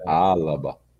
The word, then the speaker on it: Alaba: é uma pronúncia longa, porque é vogal, consoante, vogal, consoante, é, é. Alaba.